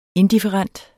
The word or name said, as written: indifferent